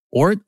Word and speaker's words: Oort: Now, Daniel, is that pronounced Oort or Oort? Because it's spelled O-O-R-T.